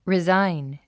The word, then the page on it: resign: rizáin; リザァイン